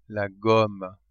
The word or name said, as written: gomme